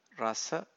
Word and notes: rasa: Pali

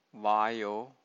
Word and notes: vayo: Pali

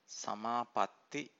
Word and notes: samapatti: Pali

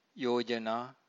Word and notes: yojana: Pali

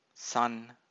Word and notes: san: Pali

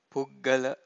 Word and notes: puggala: Pali

